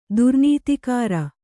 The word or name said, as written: ದುರ್ನೀತಿಕಾರ